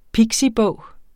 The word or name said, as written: pixibog